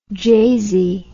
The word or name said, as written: jasy